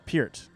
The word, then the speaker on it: Peart: That's his actual last name. That's the pronunciation. Peart. Everybody says Peart, but it's Peart.